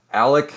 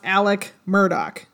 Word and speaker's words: Alex Murdaugh: Guilty or innocent, you suck because his name is spelled A-L-E-X, Alex. – Yeah. Right. – And I'm going to have to continually remind myself to say Alec because that's apparently how it's pronounced. – Yeah. I did want to take a second to issue apologies to anyone who cares. I did lead this episode last time and I mispronounced both his first and last name because it looks it looks like Alex Murdaugh, and it's pronounced Alec Murdoch.